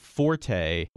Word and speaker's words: forte: And it's spelled F-O-R-T-E. We pronounced it forte with the stress on the first syllable. Often, of course, I hear it pronounced forte when people are talking about somebody's strength, somebody's strong point.